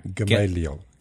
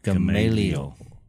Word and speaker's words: Gamaliel: Gamaliel. – Gamaliel.